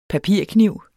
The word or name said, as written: PAPIRKNIV